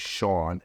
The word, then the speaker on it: Shawn: Man, Instagram, my name is Shawn, S-H-A-W-N. My last name, even though it's pronounced Mike, is M-E-A-I-K-E.